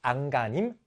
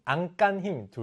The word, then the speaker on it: 안간힘: 안간힘, 안간힘 둘다 표준 발음입니다.